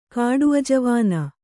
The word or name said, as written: ಕಾಡುಅಜವಾನ